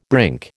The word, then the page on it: brink: American English